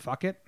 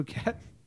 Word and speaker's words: Phuket: Fuck it. How do you say it? – Thailand? Phuket. – Phuket. It's not fuck it.